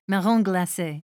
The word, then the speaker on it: marron glacé: well, I hope nobody complains about my bad French pronunciation, but I think it would be something like marron glacé.